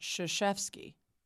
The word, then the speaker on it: Krzyzewski: Yeah, as it turns out, his name starts with a K, but it's pronounced Krzyzewski.